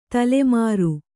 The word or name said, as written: ತಲೆಮೆರೆ